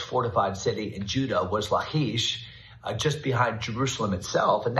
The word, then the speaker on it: Lachish: And not only that, the city of Lachish, or our Jewish friends would correctly pronounce it, Lachish.